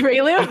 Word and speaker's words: Rayleigh: What did I say? Rayleigh or Raleigh? Sorry, I was trying to say like, sorry. I for everybody out there, we already know I pronounce things wrong. Rayleigh is the right. Rayleigh is the correct name.